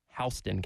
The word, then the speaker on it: Houston: In Middle Georgia, it's spelled like Houston, but it is Houston County.